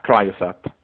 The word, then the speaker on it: CryoSat: Come si pronuncia correttamente? – Ma ci sono diversi modi, praticamente vanno bene entrambi, però noi siamo più abituati a Cryosat.